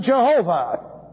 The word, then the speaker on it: Jehovah: We pronounce it Jehovah. But we put in the vowel points. They do not. Instead, we use the vowel points of Adonai and the tetragrammaton. Therefore, we come up with Jehovah.